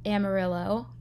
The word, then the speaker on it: Amarillo: she had this boyfriend and moved to Amarillo.